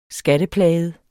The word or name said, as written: skatteplaget